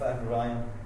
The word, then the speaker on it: Ayn Rand: You cannot exaggerate, for instance, the influence in American political thinking of Ayn Rand. Do I pronounce it? – Yes, correct. Ayn Rand, who almost dogmatized that altruism is wrong.